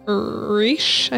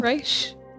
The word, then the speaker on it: Risch: And this is the disappearance of Joan Reisch, I think is how you say it. – Cool. – Reisch. R-I-S-C-H, in case you want to look at this later.